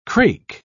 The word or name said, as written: crake